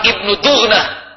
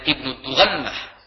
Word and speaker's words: Ibnu Dughnah: Sesampainya di Barkul Ghimad, tokoh pemimpin dan penguasa daerah tersebut yang bernama Ibnu Dughnah, ada yang membacanya dengan Ibnu Dughannah, Ibnu Dughnah kaget dan terkejut melihat Abu Bakar Siddiq datang dalam keadaan yang seperti itu, disambut oleh Ibnu Dughnah.